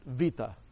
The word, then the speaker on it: beta: When we have a Greek translation of that term, they use the Greek word, or the Greek letter, we call it beta. Thanasis Aristoteles Vasilopoulos, my friend that taught me Greek, how to pronounce Greek, pronounced it Vita. So that B/V sound is the same in both Hebrew and Greek.